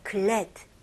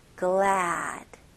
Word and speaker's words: glad: 이거는 똑같은 happy랑 똑같은 a 발음이에요. 그래서 glad. 이거보다는 입이 더 벌어져야 돼요. 그래서 glad. glad. 거기다가 a 발음을 조금 더 길게 해주세요.